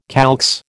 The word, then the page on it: calx: American English